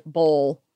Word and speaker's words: boil: she pronounces it very, she's from the area and she pronounces it bowl. And she also pronounces other related words like oil and stuff like mm-hmm.